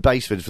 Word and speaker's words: Basford: And Baseford, for those you don't know, is spelled BASFORD.